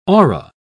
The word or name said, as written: aura